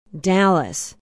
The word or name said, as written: dallas